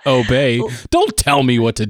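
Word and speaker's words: Obé: from an online streaming company called Obey. And it's spelled O-B-E.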